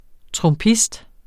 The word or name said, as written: trumpist